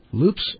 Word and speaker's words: loupes: Loops? L-O-U-P-E-S? – That's like what you use jeweler's thing to look at things. – Tell us about this. – That's how you say it, loops.